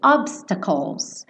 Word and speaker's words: obstacles: Esse obstacles calls, calls, tá? Esse CL, o E não vai ser pronunciado. Então, calls, bota a língua lá no céu da boca no L, tá?